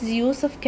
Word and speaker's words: Yusuf: Yes, it's Yusuf Karim. – Could you spell that for me? – Yes, it is Yusuf with Y-U-S-U-F and Karim is K-A-R-I-M.